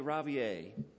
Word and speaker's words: Ravier: Virgime, I like to say Ravier. I think that's what she has told me is the correct pronunciation.